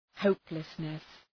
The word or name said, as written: hopelessness